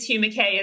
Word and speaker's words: Hugh Mackay: It is Hugh Mackay, isn't it? – It is, yes.